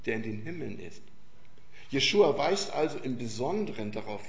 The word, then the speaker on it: Jeschua: Also ich bin, nur ganz kurz nochmal, ich benutze den Namen Jeschua und das rutscht mir mal raus, weil Jeschua, den gibt es seit 3500 Jahren nachgewiesen, wird er so ausgesprochen und Jesus,